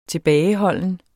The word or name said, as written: tilbageholden